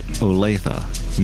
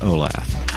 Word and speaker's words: Olathe: This is from Kansas to remind you the city's pronounced Olathe, not Olaf.